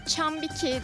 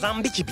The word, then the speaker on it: Zambiki: Sonra bugün de Chambiki diye okunuyor galiba. Ondan emin değilim. – Zambiki Beach.